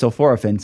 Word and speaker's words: sulforaphane: and all these years I've been saying it's sulforaphane, the first thing I learned today is, no, the academic science guys say it's sulforaphane, so I'm going to start saying it the correct way from now on.